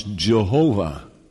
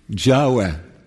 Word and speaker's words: Yahweh: Some still pronounce Jehovah. I'm not going to discuss that tonight, but I know for sure that's wrong. That's the wrong pronunciation. It is Yahweh.